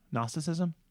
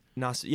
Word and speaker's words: Gnosticism: Gnosticism. Gnosticism? – Yeah, right. And what the G is silent.